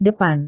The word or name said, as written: depan